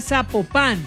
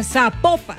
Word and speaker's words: Zapopan: Oiga, nos escriben, nos escriben los oyentes y que no se, no se pronuncia Zapopan, sino que se pronuncia Zapopan.